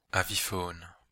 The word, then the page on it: avifaune: Belgique, Brabant wallon; [avifoːn]